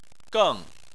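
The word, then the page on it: geng: tone 4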